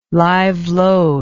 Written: live load

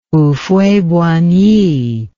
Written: Houphouët-Boigny

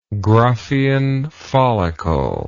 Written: graafian follicle

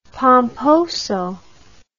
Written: pomposo

